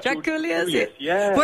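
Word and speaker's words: Drakoulias: I know with George Draculius, is that how you pronounce it? – That's right. – Draculius.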